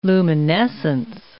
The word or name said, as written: luminescence